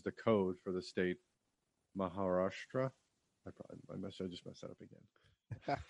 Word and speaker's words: Maharashtra: The scenes depicting Peshawar, Pakistan were actually shot in Khopoli in Maharashtra, India? – Maharashtra. – I butchered that.